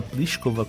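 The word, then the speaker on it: Plíšková: Quem tá vivo também, Carolina Pliskova, ou Carolina Pliskova, como na pronúncia certa, ela passou por um jogo chatíssimo contra a Shesuei em 3-7, jogou muito na reta final da partida.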